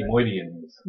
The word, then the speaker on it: Nemoidians: how to pronounce it? Nemoidians. – Nemoidians. Okay.